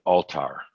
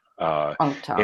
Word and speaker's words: Althar: Yeah, actually, the book, he pronounces it Altar. – Altar.